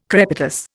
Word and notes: crepitus: Indian

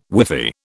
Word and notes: whiffy: British English

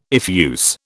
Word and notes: effuse: British English

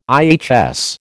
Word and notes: IHS: American English

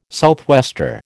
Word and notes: southwester: American English

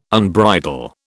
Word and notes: unbridle: British English

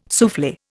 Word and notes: souffle: Indian

